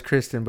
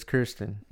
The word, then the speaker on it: Kirsten: Dunst, I always thought it was Kristen, but it's Kirsten.